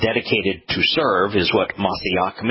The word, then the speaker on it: Mashiach: In fact, it's interesting that there is no place in the Torah, Prophets, and Psalms where Mashiach, which is the Hebrew pronunciation, is used as a title.